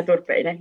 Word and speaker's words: Turpeinen: Yes, Hanna Turpeinen.